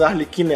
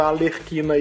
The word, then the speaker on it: Arlequina: Aliás, só pra gente corrigir, antes que alguém fale que a gente tá pronunciando Arlequina errado, é, pra quem tá aí na internet, a internet é uma merda, porque internet, veio muito, né? Nicho, né? E veio aí, teve uma época que saiu um funk quando saiu o Esquadrão Suicida, que a menina não conseguia pronunciar Arlequina, pronunciava Alerquina, e aí isso virou um meme, enfim.